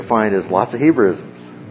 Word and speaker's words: Hebraism: That is an example of a Jewish language pattern, and the term there is Hebraism, and the idea is it's a Hebrewism, but it's pronounced Hebraism, and so it's a Jewish language pattern.